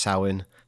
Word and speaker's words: Samhain: And he added a lot of stuff about Samhain, which we know is pronounced Samhain.